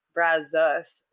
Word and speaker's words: Brazos: And I know that you're doing something with some shoes for the upcoming shoot that's happening for a Showdown on the Brazos, is how you pronounce it.